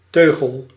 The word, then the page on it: bride: [bʁid]